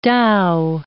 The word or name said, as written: Tao